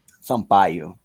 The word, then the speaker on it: Sampaio: Did I say that right, Diego Sampaio? – Sampaio. – Sampaio. – It's pretty close. – Offense, it's a name in Portuguese, okay? – It's pretty close.